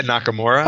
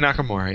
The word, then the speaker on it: Nakamura: His name, Masaya Nakamura. – Oh, Nakamura. I can say Nakamura. – Yes. – Okay. – You can say Nakamura, yes.